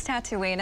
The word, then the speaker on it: Tatooine: Tawini. What is it? – It's Tatooine.